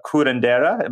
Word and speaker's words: curandera: and you've practice as a curandera. Am I pronouncing that correctly? – That's perfect.